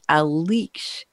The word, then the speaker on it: Aleix: who spells his name A-L-E-I-X. Alex, Axel, and Alix all on the same panel. And I promised Alix I would pronounce his name correctly rather than skipping the I and calling him Alex also.